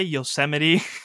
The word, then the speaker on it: Yosemite: And I did my whole presentation calling it Yosemite. – Ah. – And the teacher actually stopped me in the middle. She's like, are you trying to say Yosemite? I was like, is that how it's pronounced? – That's great.